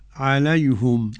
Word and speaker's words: عليهم: عليهم هنا بقى، عليهم أولا ضم الهاء لأن قبلها ياء دينية، لكل من حمزة رضي الله عنه ويعقوب الحضرمي. – تنطق كيف فضيلة الشيخ؟ – عليهم.